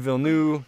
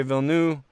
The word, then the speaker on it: Villeneuve: However you want to pronounce it, as long as you're referring to the correct person, that's okay with me. I prefer to say Villeneuve. I've heard him say Villeneuve, so it's fine.